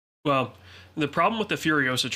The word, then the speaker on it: Furiosa: Hold on, how do you say that first one? Furosa? Well, Furiosa.